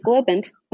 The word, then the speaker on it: Globant: You did pronounce it correctly. It is Globant.